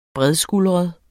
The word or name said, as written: bredskuldret